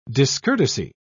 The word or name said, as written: discourtesy